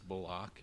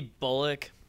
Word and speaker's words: Bullock: It used to be Bullock, and at some point it changed on the pronunciation guide to Bullock, but I just can't get my mouth to say Bullock easily.